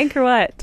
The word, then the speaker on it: Angkor Wat: Angkor Wat? Did I say it right? – Yeah, Angkor Wat.